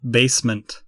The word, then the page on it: basement: American English